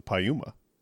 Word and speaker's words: Piuma: And then there's also Uma Thurman, and her name is spelled U-M-A, so Pi Uma. So to me, it's the Piuma. – Yeah. – Good to me. – Yeah, but you have to say it right. I mean, it's Piuma. – Piuma.